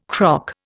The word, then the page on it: croc: Indian